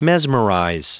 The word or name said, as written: mesmerize